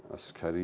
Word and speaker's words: عسکری: عسکری میگن به حضرت، که درستش هم با کاف هست دیگه، چون اصلش عربی است، عسکر گاهی با کاف تلفظ میکنن.